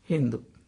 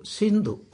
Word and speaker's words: Sindhu: And for them, this Shah, she, they could not pronounce it. They started pronouncing it Hindu. So, brother, what is the name of, what do you call this river? Sindhu. Oh, Hindu. No, no, not Hindu. Sindhu. Oh, Hindu. That is how Hindu became.